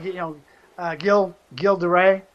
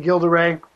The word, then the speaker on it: Gilles de Rais: Properly, Gilles de Rais. We can say Gilles de Rais. Forgive me, my French is not very good. – It is mine. It's okay. – Giles, however you want to pronounce his name, de Rais. It's G-I-L-L-E-S-D-E-R-A-I-S, a.k.a. Bluebeard.